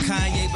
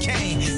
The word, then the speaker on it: Kanye: Now all I need is y'all to pronounce my name is Kanye, but some of my plastic still say Kane.